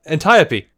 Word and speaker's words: Antiope: Antiope? Antiope? – No, Antiope. – Antiope? – Yes. – I got all the letters right, but not the pronunciation.